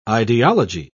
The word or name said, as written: ideology